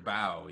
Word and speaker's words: Bao: Is it pronounced Bao or Bo? – Fuh? Wait, what are you talking about? – The short from The Incredibles 2. – Oh, the short, Bao, yeah.